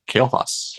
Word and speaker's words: Kael'thas: Kael'thas. – Kael'thas.